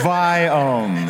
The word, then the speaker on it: Viome: It's Viome.